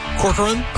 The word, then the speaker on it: Corcoran: I can never say her last name right. Barbara Corcoran? – Corcoran? – Yeah, that's exactly how you say it.